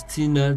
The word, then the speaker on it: Tsina: It's G-L-O-I-R-E, that's Gloire. It's Glory, translated Gloire in French. And my surname is Tina, T-S-I-N-A.